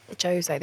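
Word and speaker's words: José: Is it Jose? – No, no, Portuguese. – What do you mean? – Jose. They pronounce the J's.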